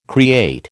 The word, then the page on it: create: American English